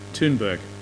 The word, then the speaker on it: Thunberg: Thunberg, yeah.